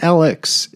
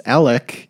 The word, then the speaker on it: Alec: And that's, you pronounce his name, it's not Alex, it's Alec, even though it's an X, and Murdaugh, even though it's A-U-G-H, is also with a hard C at the end. So it's Alec Murdaugh.